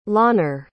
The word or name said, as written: lawner